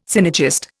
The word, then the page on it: synergist: Indian